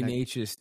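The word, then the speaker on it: Nečas: I think, you know, Martinick and Nekas are how do you say it? – Marty Natchez, dude.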